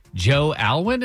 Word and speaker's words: Alwyn: Number 5, Taylor Swift's new beau, Joe Alwyn, is that how you say it, Drex? – That's correct.